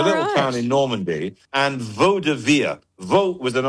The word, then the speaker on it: vaudeville: I've never been sure if it's Vaudeville or Vaudeville. – Vaudeville. Vaudeville is the correct pronunciation.